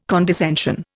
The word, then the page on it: condescension: Indian